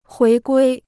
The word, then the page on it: 回归: huí guī